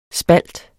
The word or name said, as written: spalt